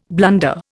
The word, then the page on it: blunder: Indian